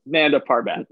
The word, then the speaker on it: Nanda Parbat: Shiva beat him and then sent him off to Panda Narbat? I'm saying it wrong. What is it? – Nanda Parbat. – Nanda Parbat. I knew I had it wrong.